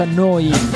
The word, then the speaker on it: Neu: Si scrive Neu, si pronuncia Noi, e vuol dire nuova, nuova emittente urbana.